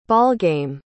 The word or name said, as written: ballgame